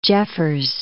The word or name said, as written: Jeffers